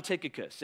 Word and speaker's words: Tychicus: The pronunciation of this name is a disagreement. I heard four different versions this morning as I was trying to, like, how do you say this name? I landed on Tychicus, and Vinny came and asked me, hey, what did you land on?